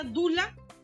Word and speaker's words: Doula: Entonces, como la pregunta tuya era, se pronuncia Dula, pero en realidad se escribe Doula